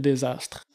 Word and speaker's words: Dsastr: Euh, comment s'appelle ton compte euh, Phil Désastre. – Phil Désastre. Puis Désastre, ça s'écrit D-S. – A-S-T-R. – D-S-A-S-T-R.